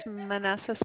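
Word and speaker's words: Manese: My last name is Manessa, spelled like Manice, M-A-N, like Nancy, E-S, like Sally, E, like Edward, and the zip code 08502.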